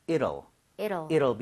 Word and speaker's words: it'll: 이 T 사운드가 약간 플랩이 나요. 그러니까 ㄹ 비슷하게 발음이 돼요. – 네. – 그래서 저는 it will 이렇게 하는 것보다는 it'll be over 이런 식으로 이럴 수가 하는 것처럼 아.